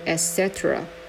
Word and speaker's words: etc: 其实这个 etc 它是一个拉丁文，它是念作 etc 啊，但是我们不用去理会它到底怎么拼的，因为没有人把它拼出来。